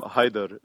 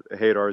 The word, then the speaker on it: Haidar: um, Haider is uh, how it's how it's like originally pronounced, but Haidar is easier to say for uh, people without the accent.